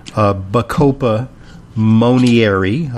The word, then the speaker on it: Bacopa monnieri: Keen Mind is the name of a supplement that incorporates Bacopa monnieri.